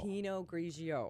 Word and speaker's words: Pinot Grigio: Pinot Grigio.